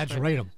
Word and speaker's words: ageratum: ageratum, am I pronouncing this ageratum. – Right? Ageratum, thank you.